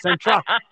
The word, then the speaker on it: Dzintra: It starts with a D and a Z, but it's pronounced with an S. Help us dumb Americans out, Dzintra.